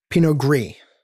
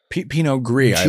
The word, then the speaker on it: Pinot Gris: But this is a Pinot Grige. – Pinot Gris. – Pinot Gris, G-R-I-S, I apologize. – G-R-I-S, the right. S is silent because it is en français.